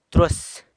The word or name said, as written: trus